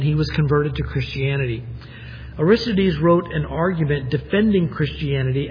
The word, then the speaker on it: Aristides: It's A-R-I-S-T-I-D-E-S, Aristides.